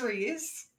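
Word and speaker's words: Rhys: Reeseland. Reeseland? Reese? – There's no L in his name. – Bro. – It is not Reese. – It is Reese.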